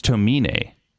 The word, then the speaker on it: Tomine: And the author, the artist is Adrian, I think, Tomine, if I'm saying the name right. – I think it's Tomine. He's from Sacramento.